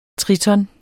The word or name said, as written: triton